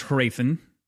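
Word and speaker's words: Hrathen: I overpronounce the H. I think it's like a subtle H, like a Hraethen. Like it's very subtle, but it's there. It's not completely silent.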